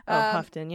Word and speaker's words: Houghton: Houghton, yeah.